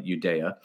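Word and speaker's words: Uyeda: Well, this was interesting because, yes, the two Republican commissioners, Hester Pierce and Mark Udea, yes, I am pronouncing his name right, Udea,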